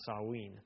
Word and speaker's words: Samhain: So when we talk about the history of the holiday, we need to start with Samhain, or as it's really actually correctly pronounced, Samhain. But I'll pronounce it Samhain. It is spelled Samhain, and that's a Celtic word.